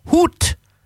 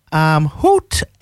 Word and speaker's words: goud: This is, now it's spelt G-O-U-D, but I was advised this afternoon from a colleague who has Dutch ancestry that it's pronounced hoot, which means gold, hoot, and the song is called Hoot by Suzanne and Frick.